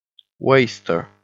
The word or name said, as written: waster